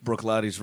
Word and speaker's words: Bruichladdich: That is spelled, just so you know, BRUICHLADDICH. – Yeah. – Pronounced Brook Laddie.